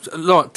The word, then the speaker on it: Laurent: But it's very funny, because it's the first time I hear my first name pronounced correctly, in English, I mean. – Okay, so how does it normally, right? How does it normally get pronounced? – Laurent, actually, like John just said.